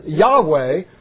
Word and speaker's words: Yahweh: Well, the name of God is four consonants, and the best we can do to pronounce it is Yahweh, or we get our word Jehovah from it.